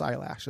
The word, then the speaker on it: Eilish: It's pronounced eyelash as well.